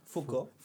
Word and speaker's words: Foucault: Foucault.